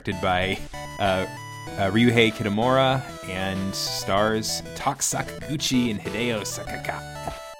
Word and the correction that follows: Sakaki: And Hideo Sakaki, or okay, yeah, cool.